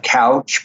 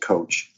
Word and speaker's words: Kowch: It's spelled K-O-W-C-H. It looks like couch, but she pronounces it coach.